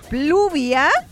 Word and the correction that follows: Publia: No es Pluvia, es Publia.